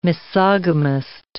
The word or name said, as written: misogamist